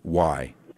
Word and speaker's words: Halley: Yeah, it's Halley. – Okay. More like Halley.